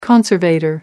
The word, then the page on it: conservator: American English